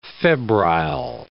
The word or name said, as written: febrile